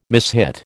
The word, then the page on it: mishit: American English